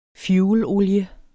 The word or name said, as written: fuelolie